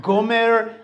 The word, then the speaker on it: Homer: I'm like, Homer. I go to the Russian. I'm like, Gomer.